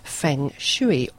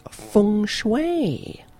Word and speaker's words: Feng Shui: Now this week we are going to talk about Feng Shui, or as it should be pronounced, Feng Shui.